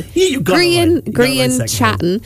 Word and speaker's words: Grian Chatten: The band's frontman, Grian Chatten, am I pronouncing that rightly? – Grian Chatten says working with the Spanish superstar is one of the biggest moments of his career.